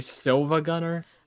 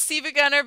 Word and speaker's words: SiIvaGunner: She pronounces it Siva Gunner, but it's actually Silva Gunner. – I mean, it's pronounced Siva Gunner because, like, the L is actually a capital I.